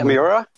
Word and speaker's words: Miura: Kentaro Miura. Am I saying that right? – Yeah, Miura.